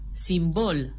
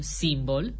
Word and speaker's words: simbol: după ce repetă asemeni altor persoane știutoare că se spune corect caracter, nu caracter, rucsac, nu rucsac, simbol, nu simbol, Oana Nicolae dă lovitura de grație.